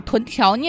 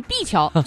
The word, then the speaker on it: 臀桥: 但是昨天在节目当中听到天乐老师把臀桥念成碧桥，就知道他的自律也是相当有限的，好吧？